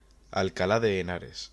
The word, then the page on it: Alcalá de Henares: Spanish